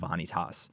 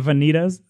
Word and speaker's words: Vanitas: Are you sure that's how it's pronounced? It's not Vanitas? – It's not Vanitas like fajitas, bro.